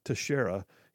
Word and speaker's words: Teixeira: Here's where we know that the kid, who his name is spelled, it's unusual, got an X in the middle, but apparently his name is pronounced Teixeira.